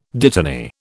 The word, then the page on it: dittany: British English